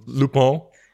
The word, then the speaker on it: Lupin: It's Lupin, correct, right? And it's pronounced Lupin.